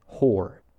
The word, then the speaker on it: Hoare: So the South African government hired this guy named Mike Hoare, that's spelled H-O-A-R-E,